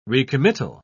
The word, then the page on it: recommittal: British English